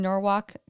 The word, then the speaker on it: Norwalk: So we also had the opportunity recently to finally, after a lot of talk from my brother, visit Space Cat in Norwalk, Connecticut.